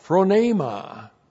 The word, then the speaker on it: phronema: This is one of Paul's words, nobody else uses this except the Apostle Paul, phronema. P-H-R-O-N, long E-M-A, phronema.